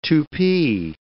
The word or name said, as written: Tupi